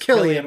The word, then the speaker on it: Cillian: It's not Cillian. It's Cillian, Cillian Murphy. Right? Okay. It's just spelled with a C.